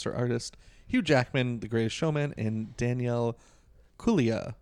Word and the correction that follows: Kaluuya: Am I pronouncing that right? Kaluuya. So. Kaluuya. Kaluuya.